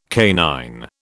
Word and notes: canine: British English

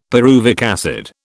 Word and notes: pyruvic acid: British English

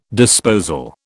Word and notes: disposal: British English

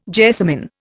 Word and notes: jessamin: Indian

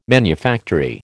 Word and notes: manufactory: American English

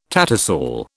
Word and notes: tattersall: British English

